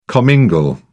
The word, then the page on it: commingle: British English